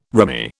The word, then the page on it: rimy: British English